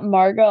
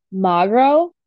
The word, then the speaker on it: Magro: It's not Margot. I believe it's pronounced Magro. It's M-A-G-R-O. So I think it's Magro.